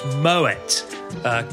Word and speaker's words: Moët: Number 2, when talking about champagne, it's pronounced Moët. Claude Moët was born in France, but his family was Dutch. And in Dutch, you pronounce the T as a hard T.